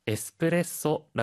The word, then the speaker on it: エスプレッソ: エスプレッソ 라 고 할 때 중 간에 조 금이 들 어갑 니다。 그 리고 ミルクティー、ジュースの 경 우에 는 자 음 기호 가 있으 니 길 게 끌어 서 발 음 연습 많이 해주 세 요。